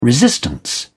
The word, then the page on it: resistance: British English